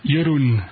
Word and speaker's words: Jeroen: Jérôme, là, je prononce ça en français, mais en néerlandais, comment ça se prononce, votre nom? – Jeroen.